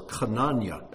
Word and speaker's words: Hananiah: A certain Ananias," or Hananiah, as you would say his name in Hebrew,